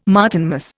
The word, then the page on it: Martinmas: Indian